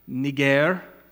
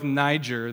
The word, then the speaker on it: Niger: It's Simeon, the second person listed there in verse 1, Simeon, who was called in the Greek, technically it's pronounced Niger, Niger. We can go with Niger, though, because of Nigeria.